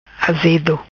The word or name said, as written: Azedo